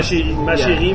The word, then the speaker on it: chérie: This is how you say chérie, right? – Chérie. Yeah. – And if you want to sound really French, you have to roll the R's.